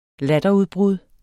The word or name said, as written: latterudbrud